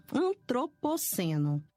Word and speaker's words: Antropoceno: Antropoceno. Antropoceno. Uma coisa a gente sabe. É uma paroxítona porque tem ênfase na penúltima sílaba.